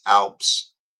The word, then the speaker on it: OUPES: I just got a new Oops, O-U-P-E-S. It's actually pronounced Alps, but the way it's spelled is like Oops.